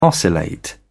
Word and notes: oscillate: British English

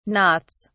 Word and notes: knots: British English

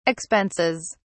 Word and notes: expenses: British English

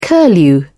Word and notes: curlew: British English

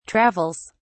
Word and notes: travels: British English

